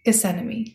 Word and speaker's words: Gethsemane: And then at the Garden of Gethsemane, I can never say that right. It's like anemone. I think I even said that wrong. Anemone? Gethsemane.